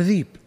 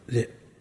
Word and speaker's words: ذئب: عندنا طالب من بيشة قبل 15 سنة أو أقل عندنا، في نقول له ابن أبي ذئب، عجز ينطق، يقول ذيب، يقول له شلون ذيب؟ ذئب،